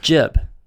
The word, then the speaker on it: Gib: It's jib. It's spelled G-I-B. – Yeah. – In my time learning the English language, that should be pronounced gib.